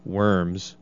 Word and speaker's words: Worms: So the emperor kind of traveled around, apparently had castles all over, but he goes to this town called Worms, or the proper pronunciation is Worms, because we're in America.